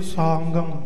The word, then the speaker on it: सांगं: गगे नालंदा उच्चारण करना है कहूं सेल सांगं